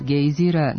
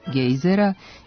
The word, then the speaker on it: gejzir: prema nazivu jednog od gejzira na Islandu istog imena. U promeni pravilni su oblici i sa kratkouzlaznim akcentom na prvom slogu gejzira, to jest gejzera, i sa dugouzlaznim na drugom slogu gejzira, to jest gejzera.